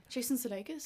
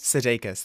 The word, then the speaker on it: Sudeikis: How do you pronounce his second name? – Jason Sudeikis. – Sudeikis, thank you.